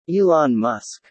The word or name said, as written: Elon Musk